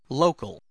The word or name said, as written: local